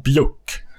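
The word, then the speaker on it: Buick: Eh, en av dem hade en, en rosa bjuck som det heter. Det är så det uttalas nämligen.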